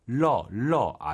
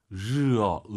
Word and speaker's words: 热: 러러 안 돼요. – 르. – 르어. 으르. 우리 한글에 으 있죠. 으 발음해 보세요. 르르. 으로 시작한다 생각하시고 르 드르륵 말아서 르어. 어로 끝내야 됩니다.